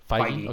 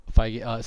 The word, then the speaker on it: Feige: you know, there's a couple that, like, it's still being produced by Kevin Feige. How do you say it? – Feige. – Okay. – Yeah. – Feige.